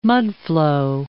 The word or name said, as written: mudflow